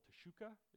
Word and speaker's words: teshuka: It's called teshuka, is the, the way that we pronounce it.